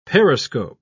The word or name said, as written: periscope